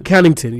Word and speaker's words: Connaughton: Got Dwight Howard, Derrick Jones Jr., Aaron Gordon, and Pat Connaughton. – Connaughton, yeah, I can say that right.